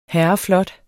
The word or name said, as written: herreflot